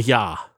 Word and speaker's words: Jaa: One of the things that I thought was interesting was Tony, and I don't know if I'm pronouncing this right, Tony Jaa? – Is it Tony Jaa? – Tony Jaa. He was Robin Chow's stunt double in the movie.